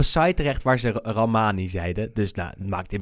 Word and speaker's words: Rahmani: Ehm, vervolgens eh, speelde ja, die naam heb ik even opgezocht hoe die je moet uitspreken. Ramani is het gewoon. Rahmani.